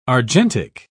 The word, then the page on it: argentic: British English